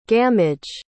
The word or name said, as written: gammage